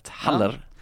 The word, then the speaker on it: Haller: Uttala H, uttala R. Haller.